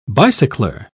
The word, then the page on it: bicycler: British English